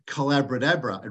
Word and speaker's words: collaboratabra: And I called it collaboratabra. It rhymes with abracadabra, the magic of a collaborative conversation.